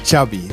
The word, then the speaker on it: Xavi: Xavi. – Yeah. – So I heard it. – Xavi like chocolate.